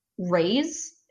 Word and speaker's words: Raix: So I want to talk about Raix. It's R-A-I-X. And that zine, that's pronounced like the Spanish word for root.